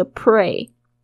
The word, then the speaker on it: pray: 你都在这个祈祷的事情，you are praying for，pray for 这个 pray。稍微注意一下它的发音，p r a y 跟那个 play 是不一样，一个是 pray，pray and play，你要特别注意那 l 跟 r 的声音，一个是 the，一个是 r，pray 就是 pray for 就是祈祷，你所祈祷的每件事情 is on its way，就是说都在路上。